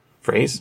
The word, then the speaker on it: Frase: Is that how you say his name? Frase? – Yes, that's how you say Peter's name.